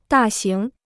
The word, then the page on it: 大型: dà xíng